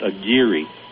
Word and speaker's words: Aguirre: Looks spelled like Hank Aguirre, but it's pronounced Aguirre.